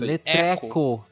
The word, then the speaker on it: Letreco: Você Põe um acento. Letreco. Tá Isso, bom. Letreco. É, é, francês. – É francês. Letreco. Por que você criou o letreco?